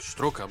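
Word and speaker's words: Strucker: to trzeba by tak Sztruka.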